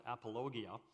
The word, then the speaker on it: apologia: The word apologetic comes from a classical Greek word, which is pronounced apologia, which is an old word.